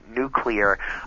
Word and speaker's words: nuclear: Yes, um, that is uh, definitely a, a pet peeve of most people who know that the word is pr- properly pronounced nuclear.